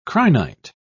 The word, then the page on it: crinite: British English